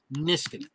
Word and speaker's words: Niskanen: We had a player on the team named Matt Niskanen. That's how it's pronounced, Niskanen.